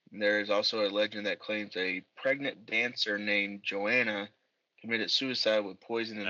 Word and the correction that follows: Johanna: I think it's Johanna is how they pronounce it.